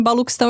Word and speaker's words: Baluchistão: Se escreve Baluchistão, mas se fala Baluquistão, é isso? Ou se escreve com o quê mesmo? – Então, é o CH, né? Que às vezes sim. A gente fala com o X, ou a gente fala o K, né?